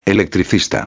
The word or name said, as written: electricista